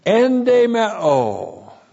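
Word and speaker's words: Endemeo (ἐνδημέω): Endemeo. E-N-D, long E-M-E, long O.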